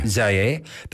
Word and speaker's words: Zaire: How do you say that? – Zaire.